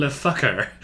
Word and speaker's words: Le Fucker: But then after that, there is an Englishman named John Le Fucker. I said that with sort of a French accent, but it's spelled, it's obviously his first name is John, and then L-E, and then space F-U-C-K-E-R.